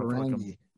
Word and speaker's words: Ferengi: Like, because Worf was part of Next Generation, but they had the, who were the Fergie? Am I saying that right? Who were they? What were they called? – Ferengi. – Ferengi, thank you.